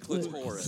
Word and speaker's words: clitoris: And you pronounce that wrong every time. The clitoris. – Clitoris. – Why do you think the O and the R are both in bold and both capitalized every time? – So this is the one that...